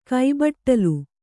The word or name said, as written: ಕೈಬಟ್ಟಲು